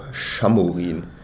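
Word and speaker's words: Šamorín: Ist ja wie Samorin, ist ja auch Schamorin.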